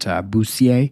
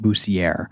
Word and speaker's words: Bussiere: So Clayton Mark Bussiere, and I know that there are some people out there who would pronounce it Bussiere, but we did speak to the family and they were very specific that it should be pronounced Bussiere.